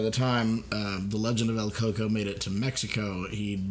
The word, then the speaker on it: El Coco: And this is the legend of El Coco, which is also Al Cuoco, and it's pronounced a few other ways also.